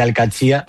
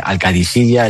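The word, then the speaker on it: Al-Qadsiah: Bueno, se suele decir Al-Qadsia. – Al-Qadsia. – Pero también hay gente que dice Al-Qadisiyya. Es que es difícil, ¿eh? Ah, no es fácil, pero Al-Qadsia es lo más fácil.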